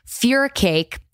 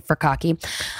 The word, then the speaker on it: furikake: January meals include spicy shrimp and Korean rice cakes with cabbage and fura cake. It looks like it's pronounced cocky but it's fear cake. They've noted that probably just for me because I've been saying furikake.